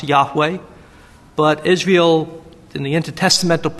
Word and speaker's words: Yahweh: And I'll say more later on about the names of God, in particular the four letters YHWH, the tetra four grammaton, four letters referring to YHWH, which was probably pronounced with the vowels A and E, Yahweh.